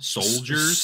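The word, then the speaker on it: Souldiers: And finally, Soldiers, but it's spelled like soul, like S-O-U-L, Soldiers.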